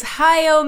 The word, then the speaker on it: Hayao: And you selected the 2004 film by how is his first name pronounced? Is it Hayao? Hayao? I think it's Hayao. – I would believe that is Hayao Miyazaki.